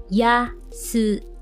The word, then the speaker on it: 安い: So the opposite of that is yasui. Yasui. Yasui. So yes, like I said, it's the opposite of takai. This time it means inexpensive, okay? Might be something cheap, okay? Yeah, so that's how you say it, yasui.